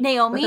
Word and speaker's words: Naomi: Naomi. – With a long A, yes.